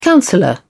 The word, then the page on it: counsellor: British English